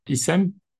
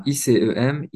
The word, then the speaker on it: ICEM: Donc en plus de ça, en parallèle, tu as récemment cofondé donc l'association ISEM, c'est comme ça qu'on prononce. – C'est ça, ICEM, Initiative au pluriel pour la communauté économique du Maghreb.